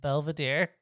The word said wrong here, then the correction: Boulevardier: Oh, I hate that. I don't. It's Boule. That's no. – Boulevardier. Yeah. Bell. – See, I can see the deer at the end. The D-I-E-R.